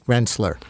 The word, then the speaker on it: Rensselaer: It shouldn't be up to us to say what they do in Rensselaer, which, by the way, is pronounced Rensselaer.